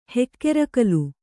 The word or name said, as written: ಹೆಕ್ಕೆರಕಲು